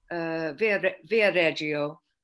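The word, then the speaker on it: Viareggio: Which is Via Reggio. Is that the correct pronunciation? Yeah.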